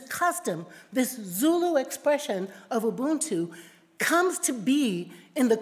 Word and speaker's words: Ubuntu: Just to impress you, I learned how to say these Zulu words, so I'm going to say it. Ubuntu, Ngubuntu, Ngubuntu.